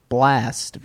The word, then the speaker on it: Blxst: And then number 34, a rapper named Blast, but it's spelled B-L-X-S-T, as to be very confusing.